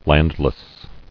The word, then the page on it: Landless: land·less